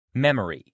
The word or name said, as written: memory